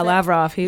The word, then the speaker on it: Lavrov: So he basically would brag to his fiance a lot, allegedly, about having dinner with Russian Foreign Minister Sergei Lavrov. Is that how you pronounce it? – Yeah, Lavrov.